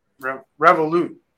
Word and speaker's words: Revolut: So moving right along, we got Revolt. Am I saying it right? Revolut. Yeah,